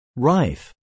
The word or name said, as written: rife